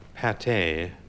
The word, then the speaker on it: pâté: Pronounced differently, like pâté, which often has a circumflex over the A and an accent aigu alongside the E, it is a mass of meat often held together with egg, flavored with onion, and as often as not, herbaceously...